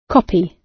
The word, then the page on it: copy: British English